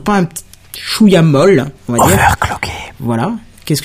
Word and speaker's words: overclocké: Tu dis overlocké, mais c'est pas ça le mot. – Ah bah si, c'est overlocké qu'on prononce, il me semble. – Cloqué. Cloqué. Bah, overclocké. Oui, il me semble. Parce que, c'est clock l'horloge.